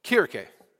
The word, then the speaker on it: Kirche: How do you say it? Kirche. Oh, very good.